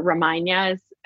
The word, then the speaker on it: Ramayana: He's the monkey god in the Ramayana, or Ramayana, as they pronounce it.